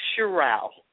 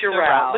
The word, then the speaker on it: Sheriow: Tell me the right way to say your last name. – It's pronounced Sheriow. – Sheriow. Okay, Sheriow. But you know what? Right. You know what? I've had people to butcher it 10 times as bad, so you don't have to feel bad.